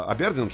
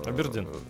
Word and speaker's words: Абердин: А он же там, у него же даже песня есть, он там, а, Абердин же город, да, где он жил? – Э, Абердин. – Абердин, ну, ударение плохо.